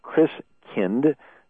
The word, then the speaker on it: Christkind: And so the German pronunciation of Christ child is Christkind, like kindergarten and kindercare.